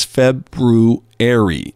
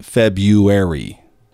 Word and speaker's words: February: As you pronounce the name of this month, she writes, both beverages are brewed, and so is the name of this month. It is February, not February.